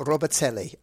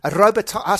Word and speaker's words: Robitaille: It's either Robitaille or Robitelli. Hi, Marcy, how are you? – Me. – So how do you say your last name? – It's actually French, and it's Robitaille. – Robitaille.